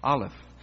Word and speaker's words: Aleph: Aleph. It's spelled A-L-E-P-H.